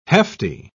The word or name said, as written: hefty